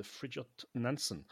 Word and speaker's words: Fridtjof Nansen: the Fridtjof Nansen.